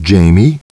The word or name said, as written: Jamie